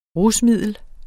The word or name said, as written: RUSMIDLET